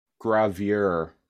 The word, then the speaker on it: gravure: I'm probably butchering their pronunciation, but gravure models. G-R-A-V-U-R-E. Gravura models? – Gravure models.